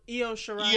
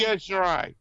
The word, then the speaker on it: Io Shirai: Then we get Kairi Sane and Los Shirai. How do you say her name again? – Io Shirai. – Io Shirai.